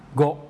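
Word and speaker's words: โก (ご): โกโรมันจิเขียน GO นะครับแต่ว่าอันนี้เป็นภาษาญี่ปุ่นไม่ใช่ภาษาอังกฤษนะครับเพราะฉะนั้นไม่ได้ออกเสียงว่าโกนะครับเป็นภาษาญี่ปุ่นออกเสียงว่าโกนะครับโก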